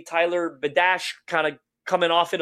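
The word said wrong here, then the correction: Biadasz: How you say his name. Tyler Bedash coming off the market a little earlier. – Biotis, that's it.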